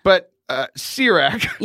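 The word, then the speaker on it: Ciroc: Sirach, I think it's made by P. Diddy or Puff Daddy. – Oh, that's C-I-R-O-C. – But that's exactly how you say it. It is a vodka. Sirach.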